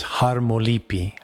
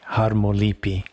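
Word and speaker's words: harmolipi (χαρμολύπη): And so it's pronounced, you know, in Greek circles as harmolipi, harmolipi.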